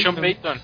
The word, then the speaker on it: Sean Payton: Bueno, de hecho, por ahí ya se suenan rumores de que Sean Payton, bueno, el coach de los Saints ya va a terminar su ciclo y que... – Sí, es Sean Payton.